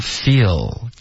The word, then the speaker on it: feel: OK 啊、比如 feel 结尾的这个字的发音啊，不要动嘴唇。 OK，很多的学生都会发成 feel。 feel，然后结尾用一个呜的音代替这个 l 的这个呜的音，呜跟呜，他们不是一个音。